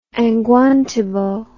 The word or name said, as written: angwantibo